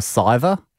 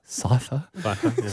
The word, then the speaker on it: Scyther: Usually something, is there one called a Scyther? – Scyther.